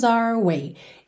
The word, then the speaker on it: our: Esse Our, aí nosso, nossa, é, frequentemente também vai ser pronunciado como se fosse a letra R. Our way, our way, ok? R, R é a letra R em inglês, certo?